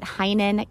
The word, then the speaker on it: Heynen: You can find me on Instagram at Heinen Counseling and Coaching. Last name is spelled H-E-Y-N-E-N.